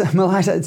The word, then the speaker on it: Malaita: So this is a region in the Solomons called Malaita. Have I pronounced that correctly? And Suadani, yes, Malaita. So and Suadani was the elected representative in the national parliament, and he was one of the few, if not the only.